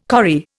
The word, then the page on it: corrie: Indian